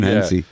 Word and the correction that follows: Henson: Well, you've said it wrong. So Gavin Henson.